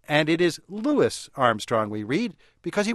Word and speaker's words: Louis: And that's the reason why he always pronounced his name Louis, as we can hear on his record of Hello, Dolly.